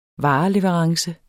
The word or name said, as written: vareleverance